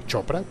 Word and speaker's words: Chopra: se pronuncia Chopra, como el famoso escritor de autoayuda, sí.